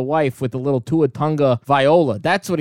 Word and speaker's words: Tagovailoa: Tugavailoa.